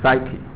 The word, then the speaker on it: psyche: 好，下面呢 psyche，这个 ps 在希腊文里面是一个字母，中文不到在希腊文里面是一个字母，啊，但是到了英文里面呢变成要两个，变成两个字母，但是念的话呢，那个 p 不发音， p 不发音，所以念 s s 变成 psyche 啊，啊，就像 ph 呢，那个在希腊文里面是一个字母，到了英文变成两个字母，那念是念成 f 的音，啊，好，psyche 呢 is also an English word in its own right.